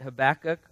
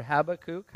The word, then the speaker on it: Habakkuk: Turn to the prophet Habakkuk, or Habakkuk, however you want to pronounce it.